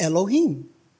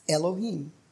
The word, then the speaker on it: Elohim: The word God, according to my theological workbook of the Old Testament, the word God, we've just been reading about, in Hebrew, it's pronounced Elohim. Okay, it's Elohim. El is the generic form of God.